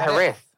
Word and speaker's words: Jerez: What I would say Jerez or Jerez. They call it Jerez. How could you get Jerez from Jerez, the way it's spelled?